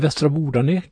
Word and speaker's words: Västra Bodarna: Det har vi i Västra Bodarna. Ni ska få veta allt om Västra Bodarna. Eller Västra Bordane kanske vi ska säga. Eh, beroende på om hur man vill uttala namnet.